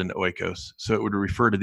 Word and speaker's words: oikos: Restoring our, and I might mispronounce the word, oikos, O-I-K-O-S, oikos.